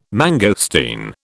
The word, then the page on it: mangosteen: British English